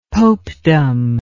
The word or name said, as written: popedom